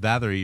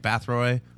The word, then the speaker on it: Bathory: Like you've been saying, like when I was talking about Elizabeth Bathory, you're like Bathroy. Like Bathrobe. – Bathroy sounds cool, but it's Bathory.